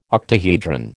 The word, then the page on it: octahedron: American English